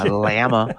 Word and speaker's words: Llama: Ride My Llama, which I always have to pronounce the way he sings it. Ride My Llama. – All right. – Because you have to rhyme it with Texarkana. That's why.